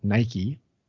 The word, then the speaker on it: Nike: Nike is victory, and today we pronounce it Nike.